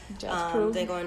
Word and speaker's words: Django: I love Django Unchained. Yeah, I love, I said Django. I'm in Django. I'm tired. – It's been a long day. – The D is silent.